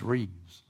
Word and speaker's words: Rives: That's spelled R-I-V-E-S. People think it's Rives, but it's Reeves.